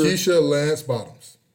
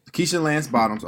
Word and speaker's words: Keisha Lance Bottoms: How you say her name? You said Keisha. – Keisha Lance Bottoms. – Keisha Lance Bottoms.